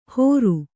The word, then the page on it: ಹೋರು: hōru